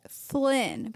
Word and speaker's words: Flynn: Dangerously close to Finn. – Not Finn, but Flynn.